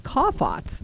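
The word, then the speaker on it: chaphets: And it's C-H-A-P-H-E-T-S, but it's pronounced kaphates.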